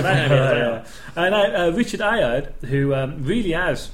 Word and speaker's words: Ayoade: Ayoade. And it says here, Ayoade.